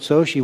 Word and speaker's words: Xochitl: And he said, "It's Soshi." And I said, "Oh, I would have never got that out of that spelling."